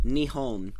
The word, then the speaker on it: Nihon: Well, in Japanese, Nihon is Japan. They don't call it Japan. It's called Nihon.